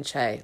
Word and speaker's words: Che: Because she looks a lot older than Che, like 25 plus years. – I'd say Che's 37. Well, we'll find. – Specific. – I've thought about it a lot. Also, Che rhymes with they.